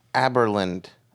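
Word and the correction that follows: Abelard: Ablard. Peter Ablard. – Ablard.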